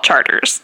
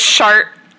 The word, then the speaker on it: Chartres: Like, I think, first of all, I think New Orleanians are correct to take back the pronunciation and call it Chartres. – Yeah, they're right. – Because Chart is a terrible name.